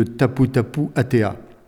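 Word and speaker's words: Taputapuātea: Le site de Tapu Tapu Atea.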